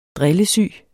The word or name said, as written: drillesyg